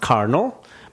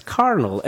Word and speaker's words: colonel: So this is a word that's pronounced carnal, but you really have to learn that this is carnal and not colonel.